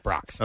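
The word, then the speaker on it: Brach's: How do you pronounce this this brand? Is it Brock's? – Yeah, Brock's.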